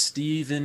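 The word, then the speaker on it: Stephen: My name is Steven Nagino, spelled with a PH, like the saint. Um, yeah, a lot of people like, is it a V? – No.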